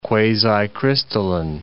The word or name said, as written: quasicrystalline